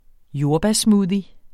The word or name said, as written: jordbærsmoothie